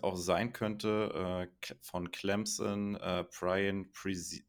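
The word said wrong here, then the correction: Bresee: Ich hoffe, ich habe den Namen jetzt richtig ausgesprochen. Du kannst mich gerne wieder korrigieren. Ja, genau, Brian Brzee, ähm, ja, ist, ist ein großer Defensive Liner in der Mitte, der wird das Laufspiel stoppen, hat auch gute Pass-Rush-Moves.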